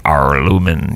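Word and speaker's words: Aurlumyn: It's A-U-R-L-U-M-Y-N. – Arlumin. Arlumin. – Arlumin, yes.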